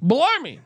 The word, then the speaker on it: Bellarmine: I'm pretty sure I've heard you guys talk about this, but Bellarmine, is that the proper pronunciation? – Bellarmine. – Okay. It's Bellarmine.